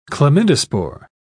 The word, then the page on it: chlamydospore: British English